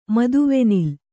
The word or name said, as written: ಮದುವೆನಿಲ್ಲು